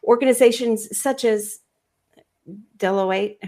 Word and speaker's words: Deloitte: Deloitte.